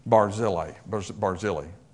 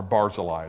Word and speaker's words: Barzillai: because I love this Barzillai. Or Barzillai is how it's actually pronounced.